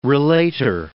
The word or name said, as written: relator